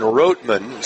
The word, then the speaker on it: Rottman: Evan Roteman, spelled R-O-T-T-M-A-N, pronounced Roteman.